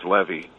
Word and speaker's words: Levy: His last name is pronounced Levy, by the way.